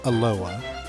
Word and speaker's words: Aloha: So there's a town in the Portland area that looks like the Hawaiian word aloha, but is actually pronounced aloha.